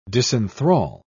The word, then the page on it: disenthral: British English